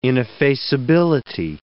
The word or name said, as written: ineffaceability